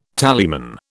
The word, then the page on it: tallyman: British English